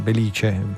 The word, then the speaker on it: Belice: Nella Sicilia occidentale è il terremoto del Belice, attenzione, l'accento è come diciamo noi, Belice.